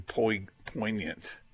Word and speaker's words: poignant: I had somebody else point out to me that uh, sometimes I have a G sound in poignant, and it's there's the G is silent.